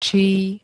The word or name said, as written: čí